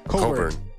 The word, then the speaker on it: Cockburn: Coburn. – Yeah. – Spelled C-O-C-K-B-U-R-N, and his name is somehow Coburn.